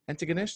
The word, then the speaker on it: Antigonish: He's a comedian from Antigonish, that's the right pronunciation. – Antigonish.